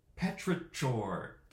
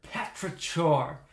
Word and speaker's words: Petrichor: How do you say that? – Petrichor. – Petrichor. Could have sworn I said Patrick for a second, but Petrichor.